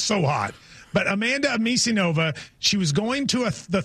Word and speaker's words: Anisimova: Anisimova. – How do you say Anisimova. – It? How'd you say it, John? – Anisimova.